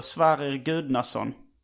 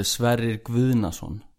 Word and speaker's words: Sverrir Gudnason: Det tycker jag var, alltså om du ska uttala det på svenska så var det rätt. Men på isländska så är det Sverrir Gudnason.